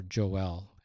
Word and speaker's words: Joel: That's the quotation from Joel chapter 2, or Joel is really the way it's pronounced in Hebrew.